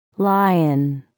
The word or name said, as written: lion